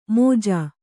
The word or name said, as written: ಮೋಜಾ